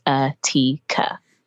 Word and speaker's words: Atika: My username is Atika, but it's my name spelled phonetically. So it is UHTEAKUH, which is Atika.